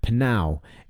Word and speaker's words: PNAU: And by this artist, I always struggle with the pronunciation of this one. Panow is how I say it. You know, P-N-A-U. What did I say? P-N-A-U. Not P-N-A-U.